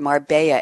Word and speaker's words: Marbella: Spanish themed restaurant about 10 minutes drive from here called Marbella, M-A-R-B-E-L-L-A or Marbella, those who don't pronounce it right.